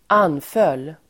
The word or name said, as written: anföll